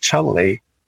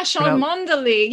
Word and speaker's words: Cholmondeley: not knowing that in Britain, that surname is pronounced Cholmondeley, which you could never have predicted. – Wait, what? – It's spelled C-H-O-L-M-O-N-D-E-L-E-Y. – Yes, yes, yeah. Yeah, Cholmondeley.